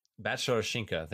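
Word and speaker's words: Batsarashkina: There is a ROC athlete, Vitalina Batshara Shinka, I believe. I can't even pronounce it. I'm so sorry. – Batshara Shinka. – There we go.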